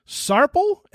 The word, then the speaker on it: Sarpel: It's Lee Sarpel. S-A-R. – Sarpel. Yeah. – P-E-L.